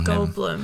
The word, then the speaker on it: Goldblum: How do you say the last name again? – Oh, Goldblum.